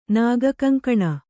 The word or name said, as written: ನಾಗಕಂಕಣ